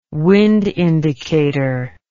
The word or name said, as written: wind indicator